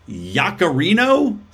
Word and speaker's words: Yaccarino: And Elon Musk just announced his new CEO, which is Linda Yaccarino. Is that how you say her name? – Sure. Yeah. – Yeah, sounds good.